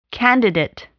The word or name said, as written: candidate